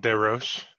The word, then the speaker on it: DesRoches: Yeah, it's French, but it's all good. People always say DesRoches.